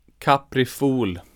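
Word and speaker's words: Caprifol: It's called Coma Capriful. – Capriful. – So, okay, yeah, so like cool. Capri and then F-O-L.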